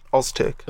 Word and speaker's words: Öztürk: Öztürk. – Öztürk. Alim Öztürk. – Is that how you say it? – Take a soft Z. It's got the little thing on. – Yeah. Nice soft Z.